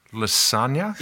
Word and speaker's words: lasagna: Like lasagna. Lasagna. It's like a Z. Lasagna.